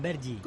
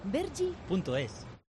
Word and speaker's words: Vergy: Con Vergi. – ¿Cómo se escribe? – V-E-R-G-Y. – Vergi.es.